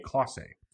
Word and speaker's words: Clase: Delino DeShields Jr., the dentist, and Emmanuel, and I saw Petriello say this, Clase. It's spelled Clase, but it's Clase.